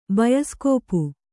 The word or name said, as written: ಬಯಸ್ಕೋಪು